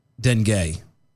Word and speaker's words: Dengue: It's pronounced Dengue, because it rhymes with Bengay.